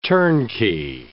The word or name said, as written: turnkey